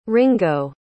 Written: ringo

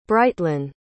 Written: Brightlyn

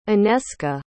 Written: Aneska

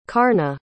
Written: carna

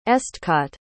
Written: estcot

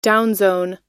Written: downzone

